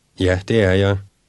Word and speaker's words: Ja, det er jeg: Ja, det er jeg. Ja, det er jeg. – Ask, are you American? – Er du amerikaner? Er du amerikaner? – Answer, yes, I am. – Ja, det er jeg.